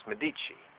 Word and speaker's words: Medici: And it was a gift to Lorenzo di Piero, and Karen told me how to pronounce this, Medici.